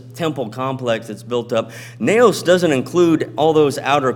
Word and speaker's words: naos: But again, we are the temple of God, and the Greek word used here for temple is naos. It's spelled N-A-O-S, naos.